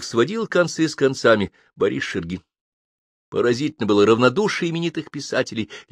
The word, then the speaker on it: Шергин: А другие, к стыду нашему, не знали, не читали и даже не слыхали этого славного имени, а если слыхали фамилию, то произносили ее неправильно, правильно Шергин.